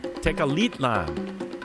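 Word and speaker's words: Tecalitlán: Mariacho Vegas to Tenochtitlan. I'm sorry, Tecalitlan. Tecalitlan. Ha! I managed to mispronounce it three times, and there was a pronunciation guide here.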